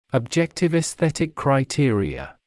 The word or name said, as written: objective esthetic criteria